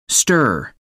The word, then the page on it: stirrer: American English